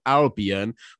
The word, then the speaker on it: Albion: And today, Jax, we're gonna quickly give a shout out to a city called Albion, which is in the US in Michigan.